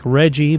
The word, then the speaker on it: Reggie: Bowles, who went by Reggie, that's spelled like Reggie but said with a hard G, was a local troublemaker, but a top notch swimmer.